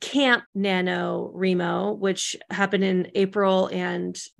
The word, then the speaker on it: NaNoWriMo: So there's this thing called NaNoWriMo, which is spelled N A N O W R I M O. It stands for National Novel Writing Month.